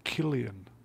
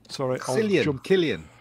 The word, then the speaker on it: Cillian: It's pronounced Cillian. – Yeah, sort it out, bro. – Sorry. – Cillian.